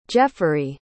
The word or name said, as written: jefferey